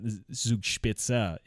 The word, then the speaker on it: Zugspitze: Zugspitze is the correct pronunciation.